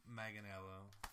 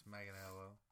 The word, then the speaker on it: Manganiello: It's Joe Manganiello. – You picked the wrong cast member. – And you pronounced his name wrong. It's Manganiello.